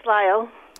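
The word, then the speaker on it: Lisle: Lyle, is it Lyle, Illinois? Am I saying that right? – Yes, Lyle.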